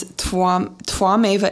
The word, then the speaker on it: Twameva: So Twameva, the first line is Twameva. It's T-W-A, but pronounced like Twa, like a V-W, Twa, Twameva.